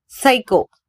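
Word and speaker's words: psycho: சைகோ PSY CHO இதனை சைகோ என உச்சரிக்கிறோம், பி சைலண்ட்.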